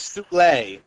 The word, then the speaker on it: Soule: Charles Soule. – Soul. It's pronounced Soul.